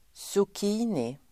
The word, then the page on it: zucchini: [(t)sok'i:ni]